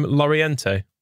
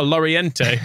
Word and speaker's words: Llorente: I think someone said that you pronounce Llorente uh, with a. It's like it's, it's you, with the Spanish pronunciation. It's like it is it need to insert like a y in it, and he's yeah.